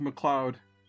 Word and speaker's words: MacLeod: And I pronounce that phonetically so that the listeners, if you leave this in, can understand what I just said. That's how you spell his name. I'm pretty sure it's pronounced MacLeod.